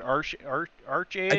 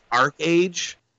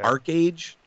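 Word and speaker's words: ArcheAge: Is it Arc-Age or is it Arch-Age? – I think it's Arc-Age. – Okay. – Arc-Age, okay. I believe. – A-R-C-H-E.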